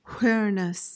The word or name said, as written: whereness